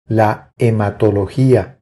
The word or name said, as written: La hematologia